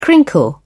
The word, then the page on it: crinkle: British English